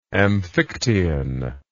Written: amphictyon

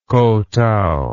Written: kotow

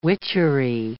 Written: witchery

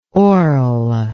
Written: orle

